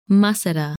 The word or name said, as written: Muscida